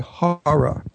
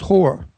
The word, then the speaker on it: horror: And I must teach how to say the word horror. – Horror. – He says whore. So he said he's a big whore fan.